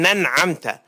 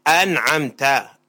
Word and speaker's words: أنعمت: عرفنا ما يصح أن يصلي خلف شخص يقول صراط الذين ننعمت، ما لا ينطق الهمزة، فنقول هذه الصلاة غير صحيحة، فلا بد أن يقول صراط الذين أنعمت أنعمت، يوضح الأحرف، فلا يأتي مثل معمعة أو يدغم في غير موضع الإدغام فيقول صراط الذين أنعمت ويسقط الحمزة، فهذه قراءته باطلة.